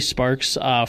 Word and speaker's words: Sparkes: It's Jamie Sparks for School Board, and my last name is spelled S-P-A-R-K-E-S.